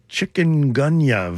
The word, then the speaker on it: chikungunya: chikungunya virus.